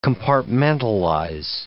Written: compartmentalize

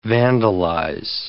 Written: vandalize

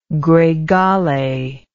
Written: gregale

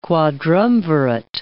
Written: quadrumvirate

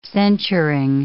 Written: censuring

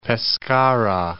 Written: Pescara

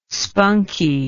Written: spunkie

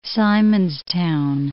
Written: Simonstown

